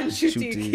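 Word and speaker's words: Ncuti: Yeah, Nshuti.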